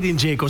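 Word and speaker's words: Džeko: Dat blijft Edin Dzeko. Zo moeten we het uitspreken.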